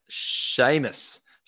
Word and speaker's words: Seamus: Tell me about Seaman Mitchell. – Yeah, so it's actually pronounced Seamus. Seamus Mitchell. – Where do I get Seaman from? I don't know. – Yeah, it's an Irish name, Seamus Mitchell.